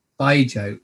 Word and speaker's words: Baijiu: Baijiu.